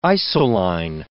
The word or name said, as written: isoline